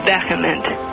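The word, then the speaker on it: VHEMT: And that's when I came across this group called the Voluntary Human Extinction Movement, which is actually pronounced vehement.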